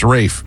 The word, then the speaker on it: Ralph: Pronounced Rafe, even though it's spelled Ralph.